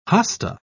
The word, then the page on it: hosta: British English